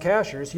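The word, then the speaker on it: Cashiers: I mean, even within counties, you know, from in Jackson County, from Silva to Cashers, you look at, and yes, it's pronounced Cashers, you look at that on a map, and it looks like, you're there in 20 minutes.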